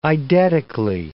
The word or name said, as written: eidetically